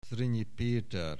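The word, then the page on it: Zrinyi Peter: Hungarian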